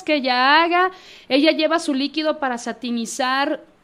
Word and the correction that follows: sanitizar: sí se dice así, ¿verdad? Eh, o sanitizar. Ay, bueno, así. Perdón, sí, sentí que lo dije mal.